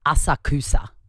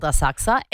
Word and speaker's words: Asakusa: There's a suburb in Tokyo called Asakusa, and it's spelt Asakusa. So phonetically, Asakusa. But yeah. You don't pronounce the U, and because I'm basically a local, I know that it's called Asakusa. Anyway, I heard a conversation on the plane on the way home where these Australians were going, oh, we stayed in Asakusa.